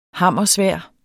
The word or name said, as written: hammersvær